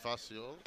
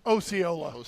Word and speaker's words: Osceola: You know how they pronounce it in Missouri? Osceola. And I said, you know, you guys are saying that wrong. And they're like, no, we're not. No, it's Osceola County. I go, dude, I'm from the dang state where he's actually from.